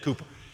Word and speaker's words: Cowper: It's spelled Cowper, but it said Cooper.